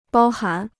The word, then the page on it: 包含: bāo hán